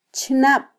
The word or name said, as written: chnab